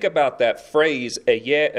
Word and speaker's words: Ehyeh: The Hebrew says, "Eyeh Asher Eyeh." Eyeh is spelled E-H-Y-E-H, Eyeh.